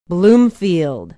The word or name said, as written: bloomfield